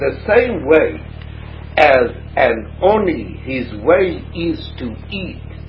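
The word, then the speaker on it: Oni (עני): Says Dovor Acher, another thing about Lechem Oni Kasiv. In the Torah, although it's pronounced Oni, it is written Ayin Nun Yud, and Ayin Nun Yud is Oni.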